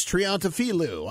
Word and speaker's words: Triantafilou: And so for the first time, let's welcome Ohio GOP Chairman Alex Triantafilou. I hope I said that correctly, to the Mark Blazor Show. Did I say it right, Alex? Hopefully. – Well, gentlemen, you did. You said it perfectly,